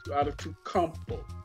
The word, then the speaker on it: Antetokounmpo: Giannis Sick of Antetokounmpo.